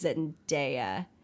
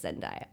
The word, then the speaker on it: Zendaya: but he mispronounces her name. He says Zendaya, but it's Zendaya anyway.